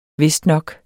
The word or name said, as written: vistnok